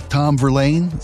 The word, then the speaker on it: Tom Verlaine: Just Tom Verlaine, am I pronouncing that right? Give him the credit due. – You are correct.